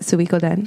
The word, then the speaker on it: Suikoden: I have always called it Suikoden. Suikoden. I think that's how the Japanese would. Japanese people would pronounce Suikoden. – It. Suikoden. Yeah. So I'm assuming. – Nanda. – I'm assuming that's it. If I'm wrong, correct me. – No, you're right.